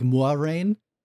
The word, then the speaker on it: Moiraine: Officially, it's sort of pronounced the French way, but not quite. It's like Moiraine.